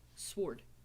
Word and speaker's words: sword: S-W-O-R-E is swore. Like, cross my heart, swore. Like, S-W-O-R-D, all of a sudden the W doesn't exist. It's a sword. – It's Sword. – W-O-R-D is word. – The W is silent.